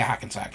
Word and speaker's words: Hackensack: Because, I mean, the people who grew up in Hackensack have to... Did I say it right? – Yeah, Hackensack. Yeah.